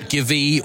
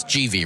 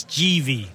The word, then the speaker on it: Givi: It's not Givi or any of these other ways. It's GV, right? No, it's GV. So the man behind the company, Giuseppe Visenzi, he was a Grand Prix racer back in the day.